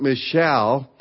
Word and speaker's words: Michal: Remember Michal, some pronounce it Michal, Saul's daughter.